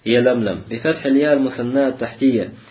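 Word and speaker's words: Ялямлям (يلملم): Говорит, пишется с буквой Я. Говорит, фаламун фамим, фаламун ухра, бада мимун ухра. То есть затем лям, потом мим, потом еще одна лям, потом еще один мим. Ялямлям.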